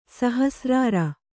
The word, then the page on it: ಸಹಸ್ರಾರ: Kannada; sahasrāra